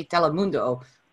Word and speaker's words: Telemundo: And tell us, how do we say your name and how do we say Telemundo the right way? – Hi.